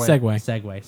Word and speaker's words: Segway: Is it Sieg or Segway? I keep forgetting. – Segway. – Sorry, I keep saying Sieg. It's not. – No, but on here at this podcast table, we're gonna pronounce it Segway, and we're gonna like it.